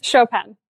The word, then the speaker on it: Chopin: I never know how to say it because I don't have the accent, obviously. And, you know, I suck at saying those kind of words. – Chopin. – Being Australian, Chopin.